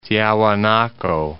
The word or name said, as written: Tiahuanaco